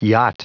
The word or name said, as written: yacht